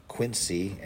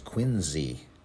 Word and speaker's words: Quincy: It's not Quincy, as in Quincy, Illinois. It's Quincy Adams, as in that town out in Massachusetts.